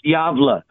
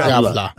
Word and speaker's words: Gävle: I think it's pronounced Yavla. – Okay. – Yavla.